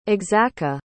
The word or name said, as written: Ixaka